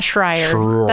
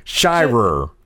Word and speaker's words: Shirer: It's not Schreier. That's how I always try to pronounce it.